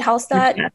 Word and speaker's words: Hallstatt: That, and I love Hallstatt. Is that yeah. how you pronounce it? Hallstatt? – Yeah.